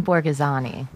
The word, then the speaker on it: Borghesani: Jim Borghesani.